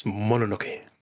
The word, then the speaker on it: Mononoke: Yes, Mononoke.